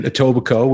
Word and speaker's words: Etobicoke: That's where he grew up in Etobicoke, which is, of course, just outside of Toronto. – Right? So he was born in Toronto, grew up in Etobicoke, proud graduate of Richview High School, which is right around the corner from where I lived for many, many years.